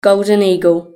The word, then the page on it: golden eagle: British English